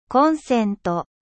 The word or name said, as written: prise